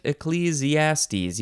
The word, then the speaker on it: Ecclesiastes: The next word is echelles, capital E-C-C-L-E-S. It's another abbreviation for Ecclesiastes. Is that how you say it? Ecclesiastes.